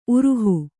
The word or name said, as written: ಉರುಹು